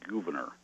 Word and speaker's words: Gouverneur: Well, I've been instructed it should be pronounced Gouverneur. – Oh, Gouverneur. Okay. – It's a Dutch name.